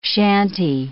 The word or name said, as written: chantey